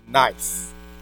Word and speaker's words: knife: And the guy stood up and he said, knife. N-I-F-E. Knife. So N-I-F-E. Teacher said, no, there's a K on there. He said, well, take that silent letter out.